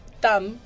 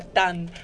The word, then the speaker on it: TAM: TAM, porque a veces con la pronunciación en portugués suena a TAN, que es N, pero es TAM con M, TAM Airlines, para los oyentes que están escuchando.